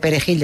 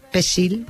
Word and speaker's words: perejil: perejil, porque aunque supieran castellano, esa palabra no sabían pronunciarla. Y si decían, en vez de perejil, decían pesil, pues, que es como lo llamaban, sí, sí, pesil, lo agarraban y lo mataban.